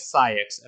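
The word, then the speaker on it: Saïx: His name is Saix, and I will spell it in the Discord because it's funky. – Syex? Oh, Nope. – okay. – SAI with two dots, X.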